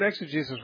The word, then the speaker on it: exegesis: I heard you were talking or explaining about, I don't know if I'm pronouncing it right, exegesis? – Exegesis, yeah.